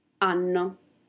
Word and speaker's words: hanno: Abbiamo, avete, hanno. So as you read, you see that there are some H's before O, I, A, and hanno, but we do not pronounce the H.